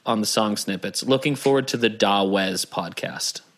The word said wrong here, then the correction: Dawes: I think that's pronounced Doze. – Oh, is it? – I think so.